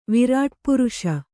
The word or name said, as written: ವಿರಾಟ್ಪುರುಷ